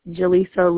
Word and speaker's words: Jalisa: Jaleesa Lewis. – Okay, how do you spell that? – J-A-L-I-S-A.